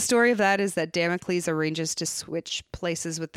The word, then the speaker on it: Damocles: Damocles.